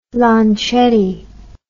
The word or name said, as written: Lancetti